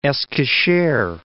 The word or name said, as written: Eskishehr